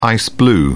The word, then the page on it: ice blue: British English